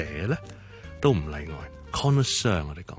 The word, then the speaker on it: connoisseur: 咁啊，当然萧叔讲嘅发音咧就系英文嘅发音，connoisseur。你可以当个两个重音，第一个重音 con，第二个重音是 sir，做 connoisseur，就是一个非常识食嘅人。